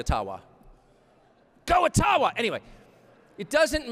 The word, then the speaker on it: Ottawa: It cannot be taken from you, no matter who is in Ottawa, or as we say, Ottawa, which, by the way, is the correct pronunciation, according to my friends in the Ojibwa community.